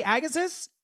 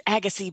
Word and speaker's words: Agassiz: Did I say that right? Agassiz? – You didn't. It's Agassiz, BC, but that's okay.